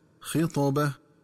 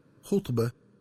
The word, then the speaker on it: خطبة: وكذلك ينبغي التنبه للنطق الصحيح لكلمة خطبة، فتقرأ بكسر الخاء هكذا خطبة، ومن الخطأ قراءتها بضم الخاء هكذا خطبة.